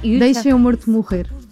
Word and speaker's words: Deixa-me Morto Morrer: It's in Portuguese, it's very hard to pronounce Portuguese, but it's almost perfect. – No, you Deixa-me Morto Morrer. We eat the vowels, so it's very hard.